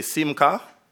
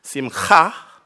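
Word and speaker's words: simcha (שִׂמְחָה): Now, if you don't want to do all of that, you can simply say simcha, but the proper way is simcha. You make that thing at the back of your throat. Okay? And simcha is gladness, jubilation, joy.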